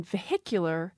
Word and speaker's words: vehicular: A good example is the word vehicle. Some speakers say vehicle. I myself have lost the H in that, vehicle. I put the H back in, though, in vehicular, because then the syllable is stressed.